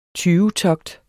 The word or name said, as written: tyvetogt